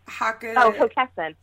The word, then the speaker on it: Hockessin: Hockessin.